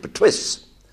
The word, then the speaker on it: Perthuis: And nobody could pronounce the name properly, Pertuis de Layeville, P-E-R-T-H-U-I-S. They couldn't pronounce it, so the illiterate English pronounced it Pertwis. So it was duly changed to P-E-R-T-W-E.